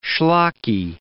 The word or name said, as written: schlocky